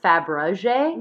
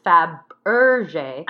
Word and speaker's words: Fabergé: I always pronounce it Fabergé. – Me too. – But that's actually wrong. It's Fabergé.